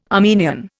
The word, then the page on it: Armenian: Indian